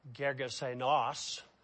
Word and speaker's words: Gergesenes: That word Gergesenes, it's actually Gergesenos, but I'm just going to say Gergesenes.